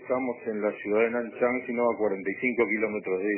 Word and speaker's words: Nanchang: Como ya quedó dicho, usted se encuentra en la ciudad de Nanchang. ¿Se pronuncia así? – Nanchang, efectivamente.